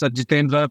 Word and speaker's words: Jithendra: Yeah, it's Jithendra Palasagaram.